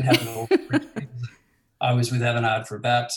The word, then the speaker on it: Avanade: And one of the first ones you had that you've listed in your bio was out in Singapore with Avanade. Did I pronounce that correctly? – It's Avanade. – Avanade. Ooh, Avanade. – Touch of French pronunciation. – Avanade.